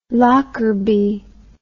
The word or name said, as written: lockerbie